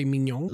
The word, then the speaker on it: mignon: Like, for example, it's the GN, because I remember the word for beautiful in French is, or like handsome, when you say mignon, like il est très mignon, like he's very handsome, you would pronounce the GN as ñ sound.